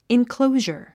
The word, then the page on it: enclosure: inklóuʒə r; インクロォジャァ